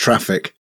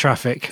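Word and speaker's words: Traefik: It's pronounced traffic. – Is it pronounced traffic? – Yeah, all right, of course.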